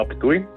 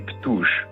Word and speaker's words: Ptuj: Ena izmed mojih najljubših situacij je povezana z izgovorjavo imena Ptuj, ki dela tujcem kar težave. In enkrat mi je skupina, pa včasih tudi kak posameznik, samozavestno rekla, da imajo radi Ptuž.